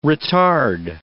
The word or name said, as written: retard